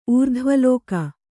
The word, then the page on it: ಊರ್ಧ್ವಲೋಕ: Kannada